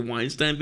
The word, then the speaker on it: Weinstein: blast, especially the ones who were doing like the Weinstein videos mm-hmm.